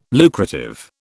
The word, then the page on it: lucrative: British English